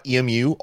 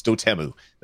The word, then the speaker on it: Dotemu: I said .emu all the time because they were doing old game conversions, and then I was watching them do a video, and they're like, Dotemu, here at Dotemu, we do this. And I'm like, I've been saying it wrong my whole entire life. So yeah, apparently it's Dotemu.